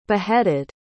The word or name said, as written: beheaded